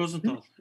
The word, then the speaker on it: Rosenthal: E, doğru mu telaffuz ettim bilmiyorum hocam Rosenthal. – Rosenthal evet.